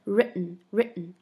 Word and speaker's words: written: 那么这里 written 这个读音啊，大家注意一下，这个 ten 吃进去了啊。那么听起来就是 written 啊。